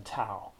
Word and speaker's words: Tao: it's spelled D-A-O. Some of you may know that. And it's pronounced Dao rather than Tao.